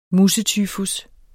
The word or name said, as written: musetyfus